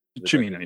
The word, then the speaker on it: Cimino: I know that I think this is Cimino. Is that how you pronounce his? – Cimino, yeah.